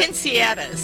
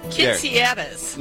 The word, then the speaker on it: quinceañeras: Quinceañeras. – Say it one more time. – Quinceañeras.